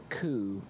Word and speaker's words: coup: Basically, how this has been described by some is that this is a coup in European football, almost like a takeover just to make more money. – I like that you use that European word with the silent P.